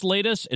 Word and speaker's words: Latus: Oh, L-A-T-U-S. – Latest. – It's pronounced latest. It's spelled lattice.